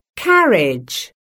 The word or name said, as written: carriage